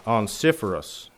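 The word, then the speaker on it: Onesiphorus: And I even asked Hal to say this, and this is how I was told, and my research said how to say it is Onciferus.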